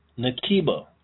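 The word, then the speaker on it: Knakeba: So her name is Nakiba. Nakiba, that's K-N-A-K-E-B-A. The K is silent, the first K for that matter.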